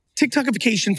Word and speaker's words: TikTokification: Probably a good segue to talk about TikTokification, if I pronounced that right. – You did. TikTokification,